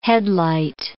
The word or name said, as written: headlight